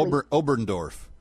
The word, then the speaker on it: Oberndorf: Oberndorf. – Oberndorf? – In Austria.